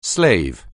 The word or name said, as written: slave